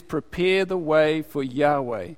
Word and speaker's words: Yahweh: Jehovah's not the best, but it means the same thing. It's the older, they thought that's how you pronounced it, but it's not the correct pronunciation.